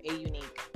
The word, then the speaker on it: Aunique: It's spelled like Onique, but it's A Unique.